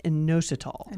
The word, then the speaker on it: inositol: And that topic is inositol. – Inositol. – My first question is, did I even pronounce that right? – You did. You did good.